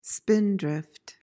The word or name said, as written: spindrift